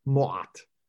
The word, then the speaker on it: Ma'at: And now you also know something about the pronunciation of the word for Ma'at, which is Mo'at.